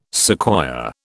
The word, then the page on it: sequoia: British English